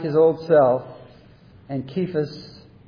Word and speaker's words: Kephas: You shall be called Kephas.'" And that's right, it's pronounced with a K sound.